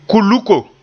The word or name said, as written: kuluko